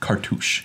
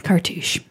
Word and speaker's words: cartouche: An enchantment subtype, cartouche. – Cartouche. – Ooh. – That's how you say it. PSA, cartouche. – Yeah, cartouche. It's not car touch.